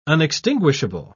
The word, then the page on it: unextinguishable: British English